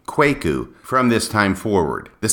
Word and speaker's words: Kwaku: If you go back and listen to that episode, you will hear that throughout I pronounced his name as Kwaku instead of Kwaku. Since the time I recorded that episode, I have been advised by a number of individuals that the correct pronunciation is Kwaku and not Kwaku.